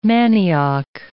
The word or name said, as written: manioc